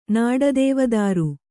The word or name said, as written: ನಾಡದೇವದಾರು